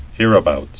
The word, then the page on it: Hereabouts: here·a·bouts